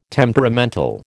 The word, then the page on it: temperamental: American English